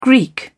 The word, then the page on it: Greek: British English